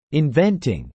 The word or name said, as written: inventing